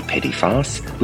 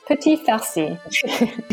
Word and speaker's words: Petits Farcis: Did I pronounce that right? – Petits Farcis.